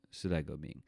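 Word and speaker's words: Sidai Go Ming (時代革命): People going to jail for slogans like Guangfu Xianggang, Sidai Li Liang. It's I said it wrong. Guangfu Xianggang, Sidai Go Ming.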